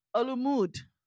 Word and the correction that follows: Olumide: Okay, so it's Olumide. I said Olumud. – It's not even that. I'm telling you how to pronounce it. It's Olumide. Olumide.